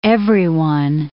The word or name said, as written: everyone